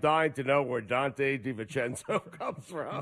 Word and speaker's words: DiVincenzo: DiVincenzo? – Excuse me.